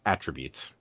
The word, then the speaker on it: attributes: you put the emphasis on the wrong syllable. I believe sure. – it's attributes.